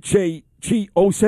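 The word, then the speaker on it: Chi Ossé: Like this idiot, Chi Ose.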